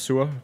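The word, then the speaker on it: Sur: And also a big fan of the Sir series, Sir Amarillo, and I like the way you guys pronounce Sir, because it's in Danish it's pronounced Sua, but you pronounce it Sir, so yeah.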